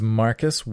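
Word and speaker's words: Markus: My name is Marcus Watson, and that's Marcus with a K.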